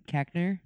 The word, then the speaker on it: Koechner: Koechner.